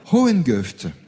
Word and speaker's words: Hohengeft: Oui, c'est bien prononcé. – On prononce comme ça. Hohengeft, dans le Bas-Rhin aussi.